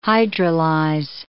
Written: hydrolyze

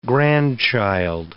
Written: grandchild

